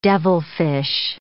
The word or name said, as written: devilfish